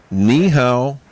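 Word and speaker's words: Ni hao (你好): Ni hao. That's how you say it. – Ni hao.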